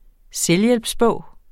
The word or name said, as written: selvhjælpsbog